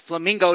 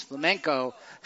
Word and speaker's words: flamenco: Now, realizing that in society, that's pronounced, a lot of people pronounce it as flamingo dancing. But that is not what it is. It's that. It's flamenco dancing.